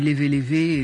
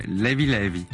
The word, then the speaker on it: Levé Levé: DJ Tombé est notre invité pour la sortie du volume 2 de ses compilations sur Sao Tomé et Principe qui s'appelle, moi je dis Levé Levé et ça très se prononce bien. Pas comme ça. Comment ils disent là-bas? – Levy Levy. – Levy Levy.